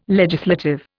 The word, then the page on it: legislative: Indian